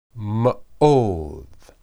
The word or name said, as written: meod